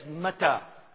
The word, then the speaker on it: mittah: And bed in Hebrew, if we were to transliterate it, take the letters over in English, it would be M-T-H. And staff is matah, and bed is matah. Staff is M-A-T-T-E-H, and bed is M-I-T-T-A-H. Staff is M-A-T-T-E-H. And bed is M-I-T-T-A-H. Mata, Mata.